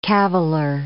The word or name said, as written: caviller